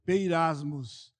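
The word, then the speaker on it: peirasmos (πειρασμός): Então, os caracteres gregos, mas não se preocupe quando você estiver revendo durante a semana, entre parênteses está a pronúncia peirasmos, que pode ser provação e tentação.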